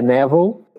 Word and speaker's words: Neville: É Neville, eu sei que é a pronúncia essa, Goddard, que é a minha pronúncia de brasileiro do sobrenome dele.